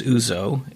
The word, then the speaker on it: ouzo: So, I am trusting Emily and Matt to say that the pronunciation is ouzo. Yeah. It's O-U-Z-O.